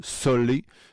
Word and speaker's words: Sole: Ça s'écrit S-O-L-E, Solé, Otero, le nom de famille O-T-E-R-O.